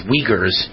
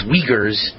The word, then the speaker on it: Uyghurs: I can never pronounce their name, but I was told by several of my wonderful friends who listen to the show every hour that it's pronounced Uyghurs. I said Uyghurs. It's Uyghurs, but it's spelled Uyghurs, but it's pronounced Uyghurs, the Muslim minority group in China facing potential genocide.